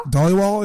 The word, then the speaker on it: Dhaliwal: Dhaliwal is... – Dhaliwal. – Yeah, like Dolly and then Wall.